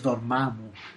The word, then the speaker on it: Dormammu: Però clar, ara aquí ja, el, quan ho fan al cinema, doncs ja hem d'aprendre tots la pronunciació correcta i és Dormammu. Exactament.